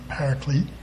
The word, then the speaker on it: Paraclete: So he's coming, and he's called the Helper, you hear it pronounced the Paraclete.